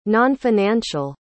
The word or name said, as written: nonfinancial